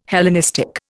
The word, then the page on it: Hellenistic: Indian